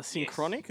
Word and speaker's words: Synchronic: So very and Psycho Gorman's another one, and Synchronic, is that how you pronounce it? They're both ones I'm anticipating. – Correct.